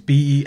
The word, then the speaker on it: Benoit: And it's spelled differently. It's Benwah.